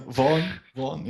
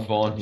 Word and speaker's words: Vaughan: gewoon bij u lachen omdat hij zijn naam verkeerd uitspraakt. Voilà. – Vaughan, ja. – Vaughan, ja.